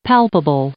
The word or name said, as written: palpable